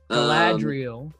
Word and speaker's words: Galadriel: Galadriel.